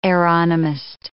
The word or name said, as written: aeronomist